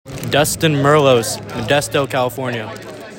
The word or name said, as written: Dustin Merlos